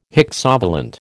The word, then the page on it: hexavalent: American English